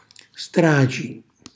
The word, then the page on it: straci: Calabrese